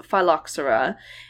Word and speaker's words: phylloxera: And I mentioned this on a previous episode, and I definitely think I pronounced the ph. So sorry, you guys, for literally. – Oh, like phylloxera. – Yep, I'm oh. – positive that's what I said. So it is phylloxera, and um, this Spanish Monastrell wine.